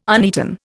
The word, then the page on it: uneaten: Indian